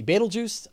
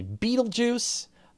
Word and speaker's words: Betelgeuse: And I say Betelgeuse, other people say Betelgeuse. And I've heard Betelgeuse, Betelgeuse, and yeah. And the pronunciation is flexible. Thanks to the movie, it's Betelgeuse, Betelgeuse, Betelgeuse. But before that, a lot of astronomers referred to it as Betelgeuse. And the original term is Arabic.